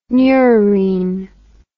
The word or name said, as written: neurine